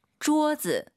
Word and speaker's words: 桌子: 桌子. – 일성경성 엄마를 생각하시면서 마마 이 발음과 똑같죠? – 桌子. – 네, 이 억양을 기억하세요. 일성경성.